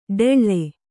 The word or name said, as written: ಡೆಳ್ಳೆ